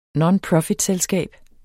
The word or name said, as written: nonprofitselskab